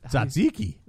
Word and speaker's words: tzatziki: Tzatziki. – Tzatziki sauce.